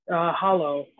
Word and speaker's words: Holo: And today we have a special guest star named Holo, if I'm pronouncing that correctly. – Hollow. – Hollow, sorry. Like it's cool. – Like the Hollow Man, but Hollow.